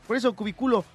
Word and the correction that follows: cubículo: No, cubí. Lleva acento en la I. – Ah, cubículo, perdóname.